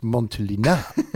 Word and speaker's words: Montelena: Mr. Newman would have gone, sorry, Turnbull, I think you'll find it's pronounced Montelena.